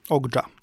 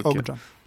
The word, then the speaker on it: Okja: Ogdża. – Ogdża? Nie Okja. – Pisze się Okja, tak, ale czyta się Ogdża.